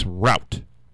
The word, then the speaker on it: route: People say root, like a root of a plant, but really it's route.